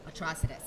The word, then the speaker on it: Atrocitus: So wait, how do you pronounce it? – Atrocitus. – Atrocitus. – Yes.